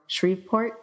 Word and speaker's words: Shreveport: Danielle O'Leary from Santa Rosa, California, and John Perkins from Shreveport. Is that how you pronounce it? Louisiana. – Yeah. – Yes. – Yep.